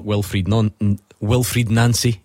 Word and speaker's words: Wilfried Nancy: We do sort of spin a lot of plates, so if you'd rather talk Wilfried Nancy, is how he says it's pronounced, or Rangers this weekend, or anything else, get in touch.